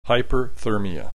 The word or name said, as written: hyperthermia